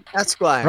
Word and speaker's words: Esquire: That's how you pronounce it. Esquire.